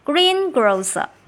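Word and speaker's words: greengrocer: Green grocer, 读音呢我们要注意一下，重音符号在前面哈，green 这个位置上哈。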